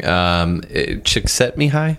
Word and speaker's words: Csikszentmihalyi: Csikszentmihalyi, is that how you say his name? – Yes.